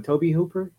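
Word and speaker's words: Tobe Hooper: First of all, it's directed by I believe you say his name, Toby Hooper. – Yeah.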